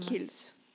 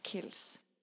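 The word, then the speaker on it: Kiehl's: У меня вот от Curls тоже э, Kills. Как оно правильно вообще произносится, эта фирма? Kills. Вот Kills.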